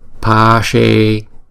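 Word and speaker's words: pāśe: Chữ A có dấu gạch ngang trên đầu cho nên chúng ta đọc hai nhịp. Và chữ E là diphthong, chúng ta cũng đọc hai nhịp. Cho nên chữ pashe là hai âm này, mỗi âm hai nhịp. Pashe.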